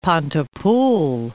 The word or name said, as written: Pontypool